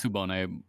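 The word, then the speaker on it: Tsubone: No, yeah, it's Subone, but, like, the distinction between the S and the TS, who fucking cares?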